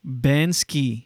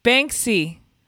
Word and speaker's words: Banksy: No, you're saying it wrong still. It's like bank? – Banksy. – No. No, like bank and then C.